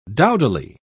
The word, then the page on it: dowdily: British English